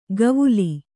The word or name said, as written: ಗವುಲಿ